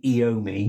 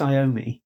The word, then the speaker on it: Iommi: And he always says, he pronounces Tony Iommi, Tony Iommi. And it's like, I'm pretty sure it's Iommi.